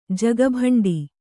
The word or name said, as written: ಜಗಬಂಡಿ